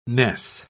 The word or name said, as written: Ness